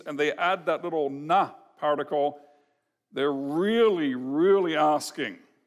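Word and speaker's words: na (נָא): And it is a little bit of language which we would pronounce in English as na.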